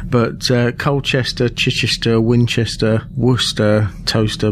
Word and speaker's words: Towcester: One of my favorite ones over here is Toaster, which is spelt T-O-W-C-E-S-T-E-R.